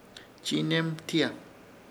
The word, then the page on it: Chiinermtiya: Ishir Chamacoco